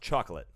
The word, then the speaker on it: Chocolat: Also that season, Chocolat. – Or like the soundtrack to the movie? – It's pronounced chocolate. – Oh, chocolate. I'm sorry. – That's how it's pronounced.